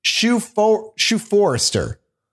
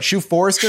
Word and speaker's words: Shoephoerster: He was accompanied by Captain Shoe Forrester. I think that's right. Shoe Forrester. – Shoe Forrester sounds right. – Yeah, but it's spelled S-H-O-E-P-H-O-E-R-S-T-E-R. It's very bizarre.